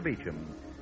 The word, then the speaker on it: Beauchamp: The main Dr. Beecham office is at 5th and Broadway in downtown Los Angeles, spelled B-E-A-U-C-H-A-M-P, pronounced Dr. Beecham.